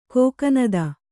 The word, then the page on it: ಕೋಕನದ: kōkanada